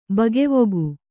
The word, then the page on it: ಬಗೆವುಗು: Kannada